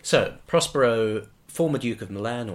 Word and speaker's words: Milan: In the play, it's supposed to be pronounced Milan. This was drummed into us right at the beginning of the performance I did, but anyway.